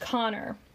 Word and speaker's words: Conchobar: and I'll spell it as it's spelled here in the Photoshop, C-O-N-C-H-O-B-A-R, pronounced Connor.